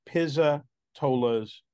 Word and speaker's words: Pizzatola's: The pronunciation is Pizza Tolas.